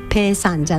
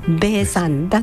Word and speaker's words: ベイ: 前を呼ぶ時、はいはい、あの時におそらくベイヨンジュンというふうに、聞いていれば、はい、日本のその韓流ドラマにハマった人たちから、はい、大勢の人たちはペイさんじゃなくて、ベイさんだと思っていたかもしれませんが、本来の読み方は、はい、ペイではなくてベイ、 BE のバビブベポのベイなんだそうです。